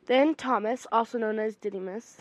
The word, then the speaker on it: Didymus: By the way, Didymus was right, because I listen to my Bible also when I read it, and that's how they pronounce it.